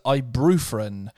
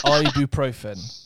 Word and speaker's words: ibuprofen: Um, first entry: people who pronounce ibuprofen as ibrofren or ibuprofen or ibuprofen. It's ibuprofen.